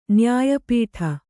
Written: ನ್ಯಾಯಪೀಠ

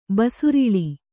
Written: ಬಸಿರಿಳಿ

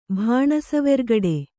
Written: ಭಾಣಸುವೆರ್ಗಡೆ

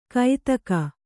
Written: ಕೈತಕ

